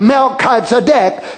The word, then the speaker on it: Melchizedek: Now the word Melchizedek actually divided in Melchi and Zedek, Melchizedek. So it divides into two different syllables there.